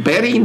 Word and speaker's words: Bettye: And Betty, in this case, is spelled B-E-T-T-Y-E. – Well, that's stupid. – Mm-hmm. – The Y must be a vowel. – The Y is silent. – The Y is silent. Yeah.